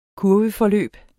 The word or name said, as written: kurveforløb